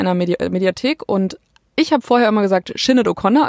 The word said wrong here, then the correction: Sinéad O'Connor: also einfach ist mir das ist mir so über die Lippen gegangen, aber tatsächlich wird sie ausgesprochen Sinead. Das ist so ihr Name Sinead oder Sinead, also ja so halt das lang lang gesprochen.